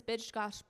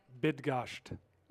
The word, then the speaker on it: Bydgoszcz: This is Bydgoszcz, Poland. I didn't say that right. – Bydgoszcz.